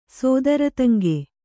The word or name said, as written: ಸೋದರತಂಗೆ